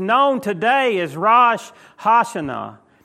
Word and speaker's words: Rosh Hashanah: I looked it up, and that's the pronunciation. Rosh Hashanah.